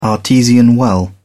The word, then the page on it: artesian well: British English